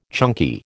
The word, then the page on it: chunky: American English